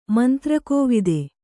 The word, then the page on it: ಮಂತ್ರಕೋವಿದ: mantra kōvida